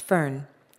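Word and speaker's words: foehn: The scientific name of this mysterious wind is Fuen, pronounced fern.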